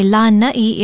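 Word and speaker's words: Lanai (Lānaʻi): Lanai is spelled L-A-N-A-Okina-I, and there is a makron or kahako, a stress mark over the first vowel. Say Lanai if you're talking about the island.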